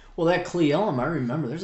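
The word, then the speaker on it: Cle Elum: It's Cleelum, isn't it? – Yeah, it's Cleelum.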